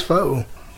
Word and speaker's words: Faux: Well, this Ken Foe, his last name is spelled F-A-U-X. – Okay. – That is Foe, right? – That is Foe.